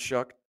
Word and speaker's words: Shough: Texas Tech, they're not going to have Tyler Shough. Tyler Shough. Tyler Shough. I don't know why it's pronounced Shuck. Shough. – Because it doesn't look like Shuck.